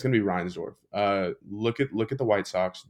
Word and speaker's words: Reinsdorf: Jerry Reinsdorf. Or did I say his name right? Yeah, Jerry Reinsdorf? Perfect, perfect.